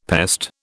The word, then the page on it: pest: British English